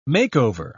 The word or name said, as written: makeover